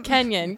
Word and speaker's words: Kenyon: Kenyon. K-E-N-Y-O-N.